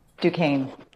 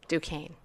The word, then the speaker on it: Duquesne: Um, how do you say your university name again? I forgot. Duquesne. Duquesne. Why is it spelled the way that it's, it's, French? – oh, it's French. Okay, just so you guys know, it's spelled D U Q U E S N E.